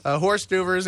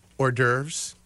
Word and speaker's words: hors d'oeuvres: So horse duvers, in case you're wondering, what is horse duvers? Hors d'oeuvres. But if you see it written down, it's like, how do you say that word?